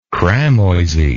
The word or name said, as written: cramoisy